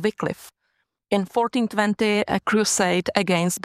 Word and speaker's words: Wycliffe: People pronounce him Wycliffe. – Exactly, yeah. And we call him Wycliffe.